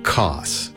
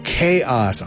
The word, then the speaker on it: K-OS: After that, Crab Bucket doing, I think it's either COS or K-OS. That's how it's spelled. We'll call it COS. Chaos? Oh, Chaos. I get it.